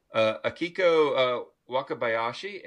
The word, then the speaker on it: Akiko Wakabayashi: Akiko Wakabayashi.